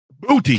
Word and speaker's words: Boutte: Booty. – Kayshawn. – No, there ain't no Boucher. It's not no Boucher. It's Booty.